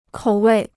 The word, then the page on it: 口味: kǒu wèi